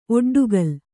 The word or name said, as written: ಒಡ್ಡುಗಲ್ಲು